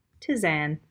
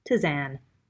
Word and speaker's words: tisane: it can be called a tisane. I think that's how you say it. Tisane? Here, let's make the computer say it. Tisane. Tisane. There you go. Tizan. T I S A N E. Tizan.